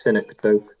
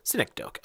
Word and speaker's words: synecdoche: I'm sorry, pronounce this correctly so I don't say it wrong 100 times. – Synecdoche. – Synecdoche. Okay.